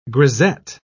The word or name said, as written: grisette